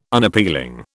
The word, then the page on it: Unappealing: British English